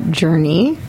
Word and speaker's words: Journée: So first, we actually pronounce it Journée. – Journée. – Which is an often switched thing, because so many of us in the industry are accustomed to the French versions of.